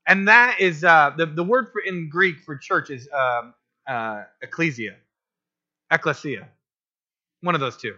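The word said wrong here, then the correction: ecclesia (ἐκκλησία): I put the wrong emphasis on the wrong syllable. Okay, ecclesia.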